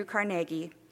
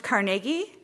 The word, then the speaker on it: Carnegie: And it's pronounced Carnegie, and everybody outside of Pittsburgh says Carnegie. And so I grew up saying Carnegie, because like Carnegie Hall, and that's what we say. I know it's Carnegie, so if I slip into Carnegie, please know that that's just the Baltimore in me, and not because I don't know how to pronounce his name.